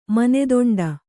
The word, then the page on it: ಮಾನದಂಡ: māna daṇḍa